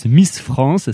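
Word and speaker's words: MSS FRNCE: ça se prononce Miss France, ça s'écrit M-S-S. FRNCE, hein, voilà, donc comme Miss France sans les voyelles à part le E de la fin, bref.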